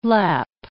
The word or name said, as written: Lapp